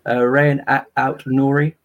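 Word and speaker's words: Rayan Aït-Nouri: Rayan Aout Nouri of Wolves.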